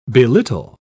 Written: belittle

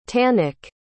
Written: tanek

